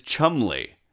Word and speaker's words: Cholmondeley: And while we're at it, Beauchamp is Beecham, Beaulieu Palace is Beaulieu Palace, and Cholmondeley is Chumley.